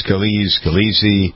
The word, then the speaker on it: Scalise: I think it probably would be, depending on whether you're Sicilian or whether you're a mainlander, it might be, that's how it's pronounced, Scalise.